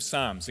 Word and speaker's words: Psalm: Yes, so we are reaching the end of this series, looking at the book of Psalms. Again, you'll notice I'm one of the first people to pronounce that word properly. It's Psalm, rhyming with ham. So that's how you pronounce it.